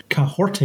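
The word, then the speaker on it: Kahhori: Cahorte is how you pronounce her name.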